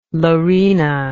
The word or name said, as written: lorena